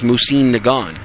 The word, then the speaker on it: Mosin-Nagant: I think the correct pronunciation is Mosin-Nagant.